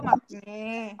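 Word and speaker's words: makne (막내): maknae maknae Oh, makne. – Oh, makne. Oh, Muji gae. – Bacanya makne.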